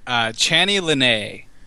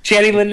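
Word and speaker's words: Channy Leaneagh: I would say Chani Lene. – Chani Lene, yeah. – Yeah, that's a good one. Chani Lene, yeah. – There's a lot of consonants in there. Chani Lene,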